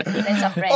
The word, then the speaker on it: Senza freni: 첫 번째 곡이 제가 발음이 맞나요? 뭔데? 센제나 프레니. – Senza freni. – Senza freni. – Correct. Senza freni.